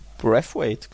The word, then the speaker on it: Braithwaite: Ja, und dann durfte auch der Herr Breathwaite, Breathwaite, wie, wie ist es? Wie ist er richtig ja. ausgesprochen? Brathwaite, glaube ich, ja. Tatsächlich, also das I, das I spricht man offenbar nicht.